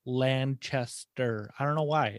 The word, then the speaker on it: Lancaster: Yeah, there's a couple different Lancasters throughout the country, but Pennsylvania is the only one that pronounces it Lancaster. Everybody else is Lancaster.